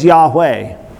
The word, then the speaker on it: Yahweh: It's called the Tetragrammaton, which we commonly produce as or pronounce as Yahweh.